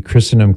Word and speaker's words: Christendom: That's their website, Christendom, the T is silent, .edu.